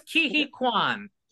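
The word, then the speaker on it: Ke Huy Quan: And tell me if I got this right with Kei Hui Kwan. Did I get the name right, please? – Yeah, you're close. Kihi Kwan. – Kihi Kwan, okay. I was not close.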